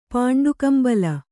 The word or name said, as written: ಪಾಂಡುಕಂಬಲಿ